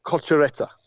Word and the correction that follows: Cocciaretto: I think that's how you pronounce it. I struggle with my own name. – I'll take your word for it. – It's Cocciaretto. – That's it.